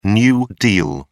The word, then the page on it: new deal: British English